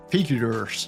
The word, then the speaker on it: Figure: c'est Figures, euh, c'est chiffres, c'est pas Figure, Figure, je crois. Bref, ça s'appelle Figure, dit prononcé en français.